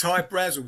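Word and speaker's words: Hy-Brasil: High Brazil, people pronounce it High Brazil, and it's Brazil, okay. – yeah. But it's High Brazil because that's how the Irish would pronounce it, because it's an island that's off the southwest coast of Ireland.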